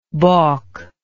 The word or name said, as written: balkingly